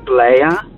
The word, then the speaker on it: Blaya: I always get Blee, Blay, Blue. My last name is pronounced Blaya, but it's funny how people don't understand to say the A at the end of it.